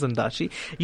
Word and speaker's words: Ondaatje: and I realized it is Ondaatje.